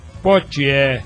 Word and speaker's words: Poitier: Gente, a nível internacional, morreu o grande ídolo nosso, Sidney Poitier. Pronunciei bem? Poitier, muito bem.